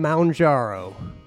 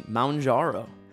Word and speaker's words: Mounjaro: Going to take a break from your Christianity today to talk about Ozempic and Mounjaro. – Good pronunciation, Mounjaro.